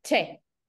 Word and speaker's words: Choi: Che, se escribe Choi, pero es Che.